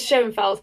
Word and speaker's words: Schoenfeld: It is Schoenfeld, because that's the one I was taught.